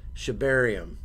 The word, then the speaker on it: Shibarium: So one of the first things we wanted to talk about was Shibarium.